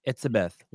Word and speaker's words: Etsebeth: Etsebeth.